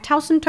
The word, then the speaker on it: Towson: Am I saying that right? Towson? – Yes, you are.